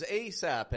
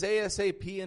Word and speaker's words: ASAP: Is ASAP an acronym or is ASAP an abbreviation? I'll tell you what, my friends, it's used both ways. This can be pronounced ASAP or ASAP. I'm telling you, native English speakers use it both ways.